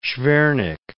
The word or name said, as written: Shvernik